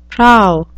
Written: prow

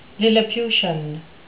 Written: lilliputian

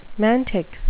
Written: mantic

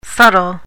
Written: subtile

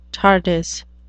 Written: tardis

